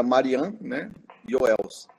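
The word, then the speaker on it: Marian Joëls: Eu falava que eu queria falar com a professora Marian Joels. Mas, nossa, eles não entendiam com quem eu queria falar, não tinha essa pessoa. É, e aí eu aprendi que a pronúncia era Marian, né? Joels.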